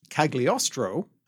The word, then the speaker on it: Cagliostro: The actual textual quote reads, "Orson Welles, working in Cagliostro," and probably that is a poor pronunciation of Italian. – Yeah. Yes, I don't think you pronounce that G, but let us not spend our time on that right now.